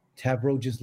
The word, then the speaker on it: Tavroges: Or if you want to email me, my address is info, at Tavroges Legal, exactly how my name is spelled, TAVROGESLEGAL, so one word, .com.